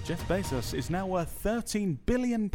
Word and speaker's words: Bezos: In other news, Jeff Bezos. How do you say his name? – Bezos.